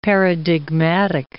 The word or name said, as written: paradigmatic